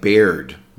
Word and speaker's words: beard: B-E-A-R-D. Be careful of the pronunciation. It looks like beard because of the word bear, but we say beard, more of an E sound.